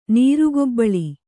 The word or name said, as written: ನೀರುಗೊಬ್ಬಳಿ